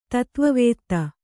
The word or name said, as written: ತತ್ವವೇತ್ತ